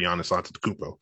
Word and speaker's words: Giannis Antetokounmpo: and the Bucks don't have their high-paid superstar Giannis Antetokounmpo.